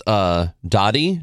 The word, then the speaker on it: Daadi: And I believe it's pronounced Dottie, D-A-A-D-I. Mm-hmm.